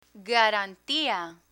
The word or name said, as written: garantia